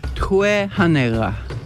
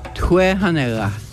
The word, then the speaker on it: tue hanega: How do you say get out of the water? – Tue hanega. – Tue hanela. – Hanega. – Hanega. – Tue hanega. – Tue hanega. – Yep.